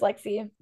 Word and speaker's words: Lexie: Yeah, it's just Lexi.